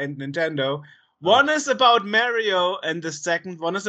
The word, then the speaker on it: Mario: It's pronounced Mario because we interviewed Charles Martinet and he says Mario himself.